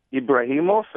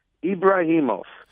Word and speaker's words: Ebrahimoff: So if you Google my name, Ruben Ibrahimov, and I'll spell it, you can find me as well. So the last name is spelled E-B-R-A-H-I-M-O-F-F, as in Frank, Ibrahimov.